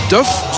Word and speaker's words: Duff: En we krijgen dan Porosso vooraan, Fofana en ook een debuut van Duff. Zo moeten we het blijkbaar uitspreken. Bachmet Duff, zoals de Duffkes van Welles vroeger, van Welles en Wee.